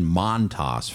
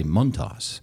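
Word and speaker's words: Montás: I went to him and I said, you know, we want to get your name pronunciation right because it's been Montas forever, but it's actually Montas.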